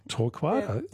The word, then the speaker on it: torquata: Torquata. – The torquata, is that how you say it? The coral gum? – Torquata.